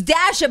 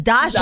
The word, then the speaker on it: Dasha: I was like, wait, that's not how you say it. – I know, because it's Dasha, but the promo says Dasha.